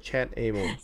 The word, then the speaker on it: ChatAmo: How do you pronounce that the software again? ChatAmo. – So it's called ChatAmo. – Mm. – ChatAmmo. – Oh, ammo. That means the bullets kind of ammunition.